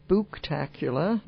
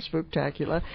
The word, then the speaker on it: Spooktacular: The Halloween Spooktacular. Nearly mm-hmm. said that wrong. Spooktacular.